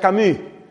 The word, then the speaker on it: Camus: El apellido se escribe Camus y se pronuncia Camus.